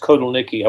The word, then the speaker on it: Kotelnicki: I'm glad you pronounced Kotelnicki. I wasn't 100% sure on that.